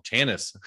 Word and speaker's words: Chanice: I have Joanna Chanice with me. I said that wrong. Chanice. I practiced before, man. – It's okay. This is Chanice sounds good.